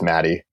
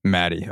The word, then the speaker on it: Maedi: Because it's just Maddie. – Maddie, okay. – Yeah, she spells it a little different.